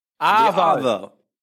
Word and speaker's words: arvo: Arvo. No. Just loosen those sounds a little bit. Get lazy, would you, with your pronunciation.